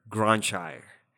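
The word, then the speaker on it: Grønkjær: It's perfect. That was perfect. I've never heard this guy's name. – Well, then I can't be wrong. – But Granshire, his last name is spelled Gronkjaer.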